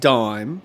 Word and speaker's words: disme: The dime was established by the Coinage Act in 1792 in the United States, but it was spelt D-I-S-M-E. – Ooh. That's stupid. – Which was still pronounced dime.